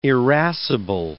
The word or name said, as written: irascible